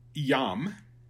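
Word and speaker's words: iam: I mean that consonant that is pronounced like a Y. So yam, not the jam that we put on our bread.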